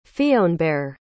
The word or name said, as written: Fionnbarr